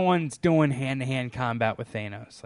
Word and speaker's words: Thanos: I don't know how to pronounce it. Thanos, right? – Thanos.